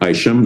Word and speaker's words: Isham: So I'm Mark Isham.